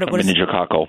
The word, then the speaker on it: meningococcal: What meningococcal.